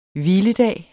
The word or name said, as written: hviledag